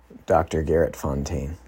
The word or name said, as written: Garrett Fontaine